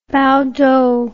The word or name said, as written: baotou